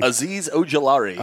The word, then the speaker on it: Azeez Ojulari: Azeez Ojulari.